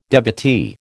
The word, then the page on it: devotee: American English